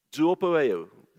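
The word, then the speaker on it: zoopoeo (ζωοποιέω): And if you read that Greek word there, it is not the easiest word in Greek to pronounce. And that word there is zoopoeo. Zoopoeo. That's what it says.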